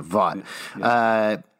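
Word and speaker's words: Vought: Turns out she was actually Voight's wife back in the day, she views Homelander as the ultimate... – Voight's wife. – What? – You said Voight. – Voight. I've been pronouncing it wrong. Vaught.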